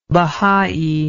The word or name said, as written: Bahai